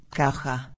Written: caja

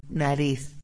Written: nariz

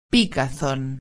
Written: picazon

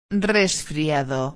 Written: resfriado

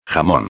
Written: jamon

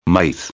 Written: maíz

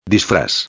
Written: disfraz

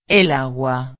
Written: el agua

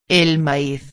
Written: el maiz

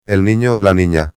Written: el nino/a

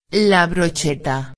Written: la brocheta

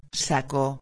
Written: saco

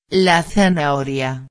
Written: la zanahoria

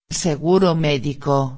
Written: seguro medico

